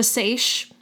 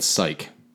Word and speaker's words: seiche: I've never heard of a sash. It is pronounced it is spelled, oh my God, I'm struggling. I'm sorry. It is spelled S-E-I-C-H-E, but it's pronounced sash. Trust me, because I looked it up.